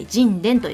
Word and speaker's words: 神田: その第 1 弾が花の花酒造さんの神殿、神田と書いて神殿と読みます。